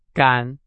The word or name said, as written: 赶